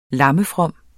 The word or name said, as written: lammefrom